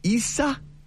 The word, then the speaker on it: Isaias: So the pronunciation is, if you want to do like a phonetic spelling out for yourself, like for those keeping score at home, it's Isaias. Yes.